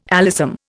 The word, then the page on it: alyssum: Indian